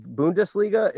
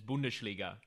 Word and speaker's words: Bundesliga: Bundesliga, is that one? – Yes, you nailed it. You nailed it. Unless you really want to make it German and it's Bundesliga, but I mean, it's up to you.